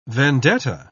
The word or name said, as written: vendetta